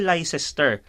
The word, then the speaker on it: Leicester: Oh, Pag yun yung pala mo siya, L-E-I-C-E-S-T-E-R, yung pala, li- so pag tingnan mo, ay Leicester.